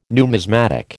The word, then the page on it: numismatic: American English